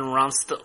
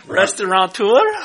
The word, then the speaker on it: restaurateur: Third generation Ronstateur. – Ronstateur. – Ronstateur. – Say it again. – Restaurateur. – That's much better.